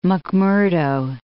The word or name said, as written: McMurdo Sound